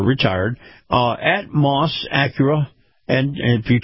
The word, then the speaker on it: Maus: In this portion of the program, our conversation with Chris Markowski, the Watchdog on Wall Street, is brought to you by Moss Acura. Moss is spelled M-A-U-S.